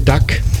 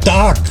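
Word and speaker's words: Dag: In the Mix für euch DJ Duck. Duck, hier schreibt er. – Dark wird es ausgesprochen wie der Tag. Dark. Das ist germanisch und heißt der Tag.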